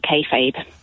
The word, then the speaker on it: kayfabe: I wanted to give you a call to have a chat about kayfabe. – Marvellous. And that is the correct way to pronounce it.